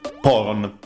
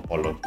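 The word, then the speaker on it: Poron (ポロン): Poron, non Pollon, cioè Pollon è la traslitterazione mm, europea.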